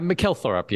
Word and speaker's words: Mikkel Thorup: Yeah, Mikkel Thorup, yeah.